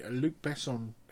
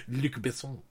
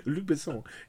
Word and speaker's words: Luc Besson: No, you said it wrong. I'm sorry. – Luc Besson. – That's better. – Luc Besson.